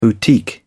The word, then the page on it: boutique: British English